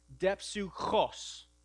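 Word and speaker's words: depsuchos (δίψυχος): This word is pronounced depsuchos. Say that. Depsuchos. You got to get a little phlegm in your throat there.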